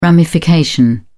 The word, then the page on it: ramification: British English